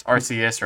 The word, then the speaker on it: Arceus: And yes, that is the definitive name to pronounce that Pokemon because it's said by multiple people in Pokemon Company and movies, so don't come at me. I hear people say it's Arceus or other things.